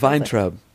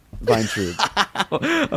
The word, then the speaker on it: Vayntrub: That's not even how she says Vayntrub. – Okay. – Vayntrub.